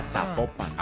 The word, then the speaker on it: Zapopan: Ah, Zapopan.